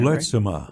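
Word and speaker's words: Lurtsema: Lersimer. Am I pronouncing that Lertzema. – Right? Lertzema. – Yes.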